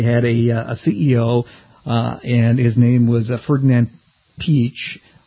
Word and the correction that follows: Piëch: I mispronounced that. It's Piak, a German name.